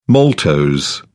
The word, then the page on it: maltose: British English